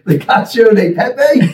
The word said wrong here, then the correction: Cacio e Pepe: There's no D. – Just the A. Cacio. – Cacio e Pepe.